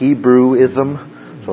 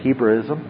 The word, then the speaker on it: Hebraism: That is an example of a Jewish language pattern, and the term there is Hebraism, and the idea is it's a Hebrewism, but it's pronounced Hebraism, and so it's a Jewish language pattern.